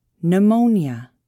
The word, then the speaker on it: pneumonia: The letter P is not pronounced at the beginning of many words. Psychiatrist, pneumonia, psychotherapy.